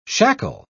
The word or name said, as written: shackle